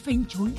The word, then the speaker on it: Feng Shui: Feng Shui.